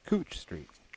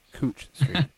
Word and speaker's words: Couch Street: This week's word is pronounced Cooch Street. Yes, you heard that right, Cooch Street. I have no fucking explanation for why Couch Street is pronounced Cooch Street, but that's how we roll here in Portland.